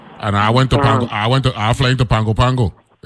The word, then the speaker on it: Pago Pago: Even though you know N in it, they don't include an N in it when they pronounce it. It's spelled Pago Pago, right? They don't call it Pango Pango, yeah.